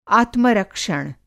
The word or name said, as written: आत्मरक्षण